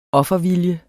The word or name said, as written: offervilje